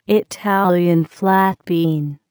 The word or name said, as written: Italian flat bean